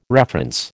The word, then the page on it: reference: American English